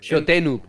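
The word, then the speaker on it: shoot 'em up: Un shoot up. Eh, entiendo que se pronuncia así, o Shooting up. – Shotenup se dice. Shotenup.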